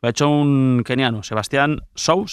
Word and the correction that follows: Sawe: ¿se pronuncia? – Sawe. – Sawe. Sawe.